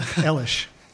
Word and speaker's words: Eilish: And then Saoirse Ronan's character, Eilish, I think that's how you're supposed to say her name. – Yeah, Eilish.